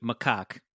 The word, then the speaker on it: macaque: Ahead, it feels sad for the monkey, which is pronounced I just listened to it, macaque.